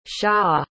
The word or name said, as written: xia